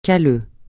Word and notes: calleux: francais

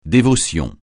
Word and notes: dévotion: francais

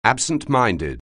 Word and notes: absent-minded: anglais

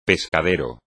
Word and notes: pescadero: Spanish

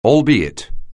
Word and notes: albeit: English

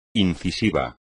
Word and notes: incisivo: espagnol

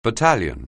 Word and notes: battalion: anglais

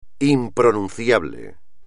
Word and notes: impronunciable: espagnol

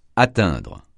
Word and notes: atteindre: francais

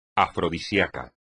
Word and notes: afrodisiaco: espagnol

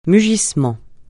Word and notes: mugissement: francais

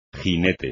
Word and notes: jinete: Spanish

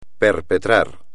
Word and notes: perpetrar: espagnol

